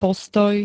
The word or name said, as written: postoj